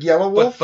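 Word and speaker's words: Yelawolf: It's spelled all fucked up. It's Y-E-L-A-W-O-L-F, but it's like Yellow Wolf.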